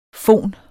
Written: fon

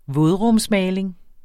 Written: vådrumsmaling

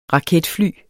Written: raketfly

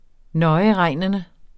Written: nøjeregnende

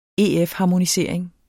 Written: EF-harmonisering

